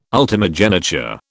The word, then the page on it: ultimogeniture: British English